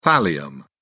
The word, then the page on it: thallium: British English